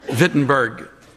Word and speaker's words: Wittenberg: Wittenberg. Over there, they use the letter W and pronounce it with a V, and they use the letter V and pronounce it with a W.